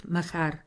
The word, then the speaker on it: Махар: Може така да го произнесете, че да натъртите на второто А. Учителят изпява втори път Махар. На второто А не дава ударение.